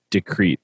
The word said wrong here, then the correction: deceit: Deceit, right? – Yeah, deceit.